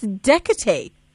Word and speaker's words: Diakité: And we've got on the line Dr. Asma Diakité. Diakité or Diakité? – It's Diakité. Good evening. – It is Diakité. – Diakité, yes. – Diakité. So more like a J as opposed to a D.